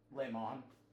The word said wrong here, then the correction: Laenor: No, Laenor. – Laenor, whatever. – It ends in an R.